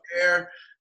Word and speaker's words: Gobert: So, you know, Donovan Mitchell and Rudy Gobert, is that how you pronounce yep. – that? – Yep. – Gobert.